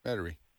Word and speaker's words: Metairie: Nacogdoches, or Metairie, maybe? How do you pronounce that? – Metairie.